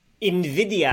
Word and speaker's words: NVIDIA: And if you're a gamer, you might know him from his work on, and I think I mispronounced this last time, NVIDIA GeForce. NVIDIA. Nvi- it's like the Spanish NVIDIA. word for envy, which is why their logo is a green eye.